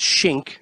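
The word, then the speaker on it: Schenk: So so you bring up Adam and it's Schenck, and it's, that's how it's pronounced.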